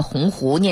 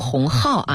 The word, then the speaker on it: 鸿鹄: 哎，回顾一下事件经过， 5 月4 号啊，在北京大学建校 120 周年的校庆上，北大校长林建华在演讲中呢，将鸿鹄之志这个鸿鹄念成鸿号啊，引发了舆论的热议。